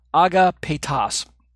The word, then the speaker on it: agapetos: I think I'm mispronouncing Agapetas. I said Peptos. I'm ending one extra letter there. Agapetas. So Agapetas and Monica Nace kind of work together to form the idea of one and only, unique and beloved.